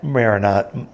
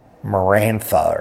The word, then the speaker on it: Maranatha: There's a publishing company, a music publishing company, and people sometimes pronounce it Marantha or something like that. Yeah, who don't see all the letters that are there.